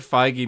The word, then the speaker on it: Feige: When I said Feige, my heart skipped a beat.